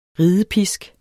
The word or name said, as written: ridepisk